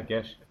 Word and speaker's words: Gish: Stuff like I think it's pronounced Gish. I want to say Gish. – Oh, sure, yeah, Gish.